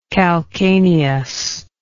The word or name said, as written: calcanean